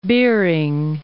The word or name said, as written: Bering Sea